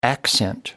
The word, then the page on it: accent: American English